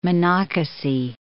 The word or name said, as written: Monocacy